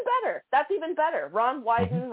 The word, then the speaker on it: Wyden: Senator Ron Wyden, if I'm saying that correct, I think it's hysterical because it rhymes with Biden. Ron Wyden.